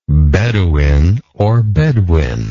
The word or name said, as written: bedouinism